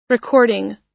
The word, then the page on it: recording: American English